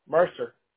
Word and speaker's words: Mercer: Mercer. – And how do I spell that? – M-E-R-C-E-R.